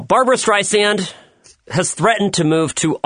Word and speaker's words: Streisand: You know, she even called Tim Cook, the CEO of Apple, and you know, whined to him about how her name is pronounced Streisand with an S.